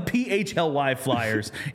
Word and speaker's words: PHLY Flyers: So PHLY Flyers is how you say it.